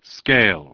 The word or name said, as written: Scale